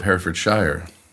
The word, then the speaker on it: Herefordshire: And yet I think you're pronouncing it really quite well for an American. they struggle a little bit with Herefordshire, but you're doing a very good job.